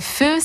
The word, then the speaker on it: pho: Ça s'écrit P-H-O. Donc feu, c'est en fait le nom en vietnamien.